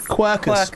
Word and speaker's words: Quercus: Quercus. – Published by Quercus.